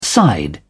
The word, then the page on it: side: British English